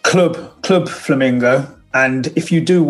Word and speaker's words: Clwb: And you'll have to tell me, is it pronounced just Club Flamingo? – Because it's spelling, which is Welsh.